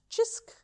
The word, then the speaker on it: Cisk: But there is a national beer called Chisk. – It's, which is spelled C-I-S-K. – Yep. So, but it's pronounced Chisk.